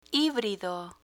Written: hibrido

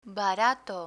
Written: barato

